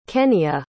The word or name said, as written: kenia